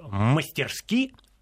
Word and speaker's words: мастерски: мастерски, ударение падает здесь на последний слог, обращаю внимание, э, э, защищает свою позицию.